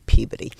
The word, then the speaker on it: Peabody: It's spelled Peabody, but you say Peabody.